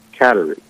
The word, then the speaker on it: Kateri: She took the name Catherine for St. Catherine of Siena, and the Mohawk pronunciation of that is Kateri. So When you see the Kateri, it's really sort of a stylization of the name Catherine.